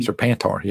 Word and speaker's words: Serpentor: after defeating Sepentor. – Mm-hmm. Serpentor, yep. I'm sorry, I mispronounced.